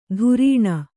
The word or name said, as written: ಧುರೀಣ